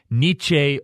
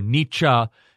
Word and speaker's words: Nietzsche: Friedrich Nietzsche, there's different ways that people pronounce it, but Nietzsche or Nietzsche is the most correct or most well-known pronunciation.